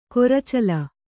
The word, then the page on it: ಕೊರಚಲ: koracala